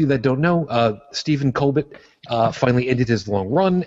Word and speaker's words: Colbert: Collectively, from all of us, Mr. How's it pronounced? Is it Colbert? – Colbert. – Colbert? – Colbert. – Mr. Colbert. – Colbert. – Colbert. I got it now.